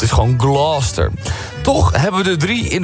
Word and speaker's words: Gloucester: De Engelse plaatsnaam Gloucester spreek je uit als... – Gloucester. – Gloucester. – Ja. – Als één vloeiend woord.